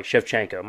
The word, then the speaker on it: Shevchenko: And so that card in general, UFC 285, so we had like the Bo Nickel fight on there, Grasso beating Shvechnikov. Shevchenko, my bad. I always mispronounce it.